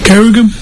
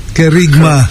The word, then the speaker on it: Kerigma: Y el retiro para jóvenes adultos de 19 a 35 años de edad, retiro de Carum, ¿cómo se pronuncia? – Kerigma. – Kerigma.